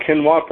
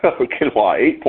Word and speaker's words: quinoa: Number six, swap quinoa for rice. That's twice I said it properly. Protein per quinoa, 8.1 grams.